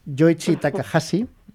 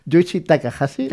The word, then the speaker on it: Yoichi Takahashi: Yoichi Takahashi, ¿vale? Gracias, Lucía, por decirme que es buena mi pronunciación de un autor al que probablemente acabas de conocer ahora. Muy bien, Yoichi Takahashi,